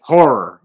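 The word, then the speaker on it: HO99O9: H-O-9-9-0-9. I sound like a fucking boomer. – I do not know. How do you pronounce that shit? Horror. Okay, it's pronounced horror.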